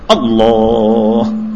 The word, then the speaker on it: اللہ: اس کا صحیح تلفظ کیا ہے؟ اللہ. ساکن کیا جائے ہے پر.